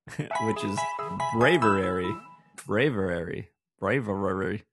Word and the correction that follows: Braviary: I think I said it right, right? – Braviary. – Braviary. That's what I said. Bravery. Mm-hmm. – You said it like Scooby-Doo would say it.